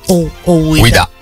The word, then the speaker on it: Ouida: Ovvero la trasposizione del 1975 del romanzo Il cane delle Fiandre di Ouida, Ouida.